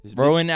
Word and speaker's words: Rowan: I think that's how you pronounce it. R-O-W-A-N. Rowan.